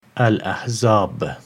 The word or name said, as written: Al-Ahzab